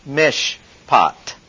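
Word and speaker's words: mishpat (מִשְׁפָּט): It's pronounced mishpat. M-I-S-H-P-A-T. And it occurs better than 400 times in the Old Testament.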